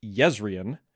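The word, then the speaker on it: Jezrien: In Stormlight, Jay is pronounced like a lot of Scandinavian languages, and so Kalak will be meeting up with Yezrian.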